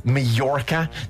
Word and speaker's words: Mallorca: But just so you know, it's Mallorca. You don't pronounce the J.